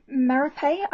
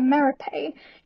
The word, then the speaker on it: Merope: So I wanted to talk about Merope. I know you guys really don't like that pronunciation. It's how I've always done it. I'm really sorry. – It's okay. – And the reason for that is because it actually comes from the Greek mythological character Merope.